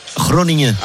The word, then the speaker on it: Groningen: Groningen.